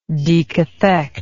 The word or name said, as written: decathexis